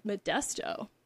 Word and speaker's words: Modesto: Modesto. That's how you say it.